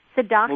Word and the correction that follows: Siddiqi: Muzammil Sadiki.